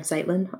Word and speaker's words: Zeitlin: I am at RF Zeitlin on Twitter.